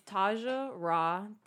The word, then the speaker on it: Tasja Ra: It's Taja Ra, T A S J A space Ra R A crystals, and Word.